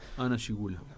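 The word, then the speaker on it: Hanna Schygulla: En su filmografía, llena de personajes femeninos inolvidables, hay que destacar algunas actrices con las que trabajó intensamente, como Hanna Gigula, ¿cómo se pronuncia? – Hanna Gigula. – Ahí está.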